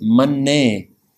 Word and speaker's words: Mannay: Let's be careful of the pronunciation here. The first verse is Mannay. It has a lam, Mannay. The second verse in this series is Mannay. The difference is Mannay, Mannay, lam, dulam.